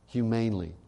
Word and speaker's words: humanely: Then on the 14th day, this innocent lamb would be slaughtered, humanly slaughtered. humanely slaughtered is the correct pronunciation of that word.